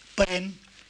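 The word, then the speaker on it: parent: Paren que se escribe, con una T que no se pronuncia. Es decir, se pronuncia en valenciano y en, balear, pero en, el resto del catalán no se pronuncia.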